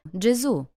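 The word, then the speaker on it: Gesù: По-итальянски Иисус произносится «Джезу» с ударением на последний слог.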